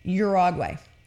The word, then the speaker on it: Uruguay: It's one of the most significant public holidays in the Oriental Republic of Uruguay, which I can, in fact, pronounce. And it does not, in fact, look at all how it is spelled. Okay? Uruguay.